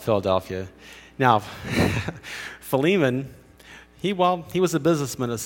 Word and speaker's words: Philemon: Now, Philemon, this is really interesting, is actually pronounced Philemon.